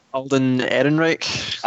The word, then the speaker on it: Alden Ehrenreich: Alden Ehrenreich.